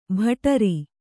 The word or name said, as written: ಬಟಾರಿ